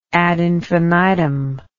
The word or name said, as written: ad infinitum